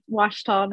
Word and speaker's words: Ouachita: It's kind of a weird word, Ouachita.